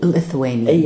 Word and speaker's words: Lithuanian: I pronounce it right, Litauen. – Lithuanian. – Yeah, Lithuanian.